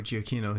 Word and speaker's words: Giacchino: So the music was done by Michael Giacchino. – Oh, I'm pronouncing that friend right. Of the podcast, Michael Giacchino.